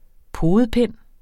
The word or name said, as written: podepind